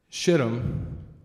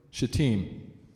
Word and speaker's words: Shittim: Joshua, he gets up early in the morning then he and all the children of Israel set out from Shittim, make sure you say that name right, and came to the Jordan, Shittim. That's it. I actually went and made sure I uh, YouTube to make sure that pronunciation was correct.